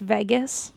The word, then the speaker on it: vagus: It's pronounced Vegas.